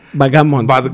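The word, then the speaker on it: backgammon: Bagamon. – Es que no sé cómo se pronuncia, Es Bagamon. Es el que son como unas bolitas y unos triángulos, ¿no?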